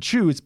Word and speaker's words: Chewse: But Chooze, by the way, which is spelled Chewse, is another play in the space that I hadn't heard of before.